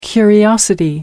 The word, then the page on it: curiosity: American English